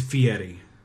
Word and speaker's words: Fieri: Sorry, it's Fieri.